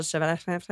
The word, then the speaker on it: Chevalier: And Hotel Chevalier. How do you say Chevalier.